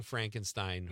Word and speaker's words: Frankenstein: I just did the young Frankenstein pronunciation. It's Frankenstein.